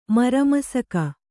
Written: ಮರಮಸಕ

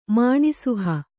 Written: ಮಾಣಿಸುಹ

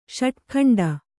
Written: ಷಡುಖಂಡ

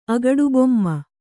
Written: ಅಗಡುಬೊಮ್ಮ

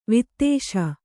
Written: ವೀತಾಸು

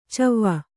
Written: ಛವಿ

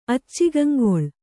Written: ಅಚ್ಚಿಗಂಗೊಳ್

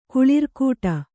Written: ಕುಳಿರ್ಕೂಟ

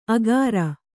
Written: ಅಗಾರ

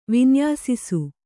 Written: ವಿನ್ಯಾಸಿಸು

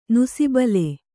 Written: ನುಸಿಬಲೆ

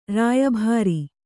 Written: ರಾಯಬೋರೆ

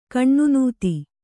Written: ಖಿನ್ನತೆ

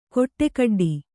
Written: ಖಡಖಡಾಟ

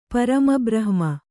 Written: ಪರಮಬ್ರಹ್ಮ

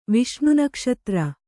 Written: ವಿಷ್ಣುನಕ್ಷತ್ರ

